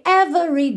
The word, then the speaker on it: every: Porque 99,9% das vezes que a gente vê a palavra every, esse E é do meio é mudo, ou seja, do V a gente vai direto para o R. Every, every, ok?